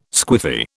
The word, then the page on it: squiffy: British English